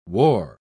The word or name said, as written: war